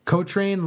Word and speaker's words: Cochrane: Cochrane